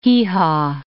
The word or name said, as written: hee-haw